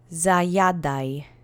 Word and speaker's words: zajadaj: So it's really like eating heartily, eating with appetite, and just that kind of tone is in zajadaj. So there are no special phonemes in this one also, and the accent is on the penultimate syllable. Let's repeat together three times. Zajadaj.